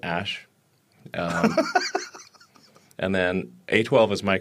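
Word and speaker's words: Æ: And, of course, what you just heard was the guy telling Joe Rogan on a podcast that really AE is pronounced Ash, while crediting his girlfriend for coming up with the bulk of the moniker.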